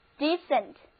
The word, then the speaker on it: decent: 注意第一个一发一的音，而第二个一不发音。跟我读一遍这个单词，就是 decent。